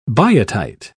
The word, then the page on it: biotite: British English